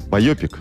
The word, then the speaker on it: байопик: Байопик. – Байопик, но вообще, это... на самом деле, ударение на «а».